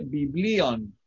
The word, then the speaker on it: biblion: Aquí dice biblion, ¿ok? Porque el acento está en la iota, que es la que corresponde a la i. Biblion.